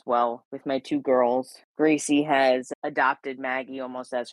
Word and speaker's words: Greycie: And the gray cat, her name is Gracie. And we spell it with G-R-E-Y-C-I-E. So it's Gracie, like her color, because she's so cute.